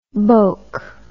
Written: boke

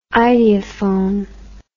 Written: ideophone

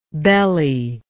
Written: bellylike

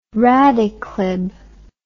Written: radiclib

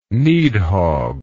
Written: Nidhogg

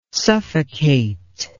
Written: suffocate

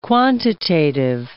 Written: quantitative

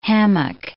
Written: hammock

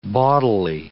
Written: bawdily

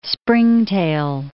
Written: springtail

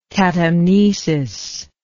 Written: catamnestic

